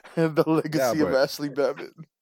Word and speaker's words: Babbitt: Oh, because like David said, Babbitt rhymes with rabbit, Easter bunny, boom, conspiracy closed.